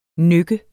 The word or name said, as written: nykke